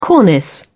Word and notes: cornice: British English

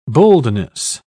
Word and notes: boldness: British English

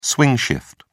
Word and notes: swing shift: British English

